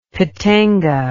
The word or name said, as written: pitanga